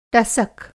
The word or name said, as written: टसक